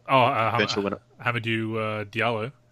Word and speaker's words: Hamidou Diallo: Hamidou Diallo.